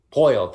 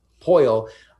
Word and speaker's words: Poile: They have a very they've had the GM that's been there since their inaugural season, and David, is it Poile, correct? Is that how you say it? – Poile. – There, I knew I was going to say it wrong. Poile.